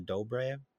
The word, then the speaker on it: Dobrev: This is a comedy around Adam Devine and Nina Dobrev. Dobrev? How do I say her last name? I always get it wrong. Dobre, I think. I don't think you pronounce the V.